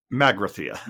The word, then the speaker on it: Magrathea: Magrathea.